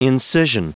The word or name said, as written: incision